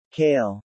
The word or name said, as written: kale